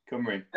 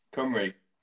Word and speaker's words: Cymru: Cymru. – How do you say it? – Cymru.